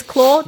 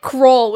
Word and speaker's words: Claw: It is Claw. – Yeah, they were pronouncing it like Crawl, which was weird.